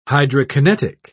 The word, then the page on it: hydrokinetic: British English